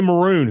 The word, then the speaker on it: Maroon: Courtney Maroon, who wanted to remind us that her last name is pronounced like the color, how far in advance will the kickoff for the State South Carolina game be decided?